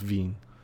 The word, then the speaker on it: Vin: I think he took Vin from wine, so it's pronounced Vin. Vin Diesel.